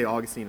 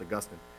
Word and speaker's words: Augustine: One of our early church fathers, Augustine, I don't know how you say that. We say Augustine in the South, but I think it's supposed to be pronounced Augustine.